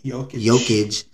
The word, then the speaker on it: Jokic: Jokic.